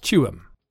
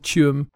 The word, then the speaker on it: Tuam: Gavan chose June because it rhymes better with Tuam.